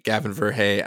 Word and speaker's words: Gavin Verhey: I'm Gavin Verhey, and I'm he, him.